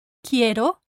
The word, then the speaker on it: quiero: And rule number four says, the letter U is silent in the cluster Q-U-I, key. Two words from previous lessons are quiero, I want, quiero, and quinto, fifth, quinto. So the letter U is silent in the four clusters G-U-E, G-U-I, Q-U-E, and Q-U-I.